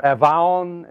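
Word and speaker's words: avon (עוון): And that word iniquities in the Hebrew, avon or avon as it's pronounced, it means a fault.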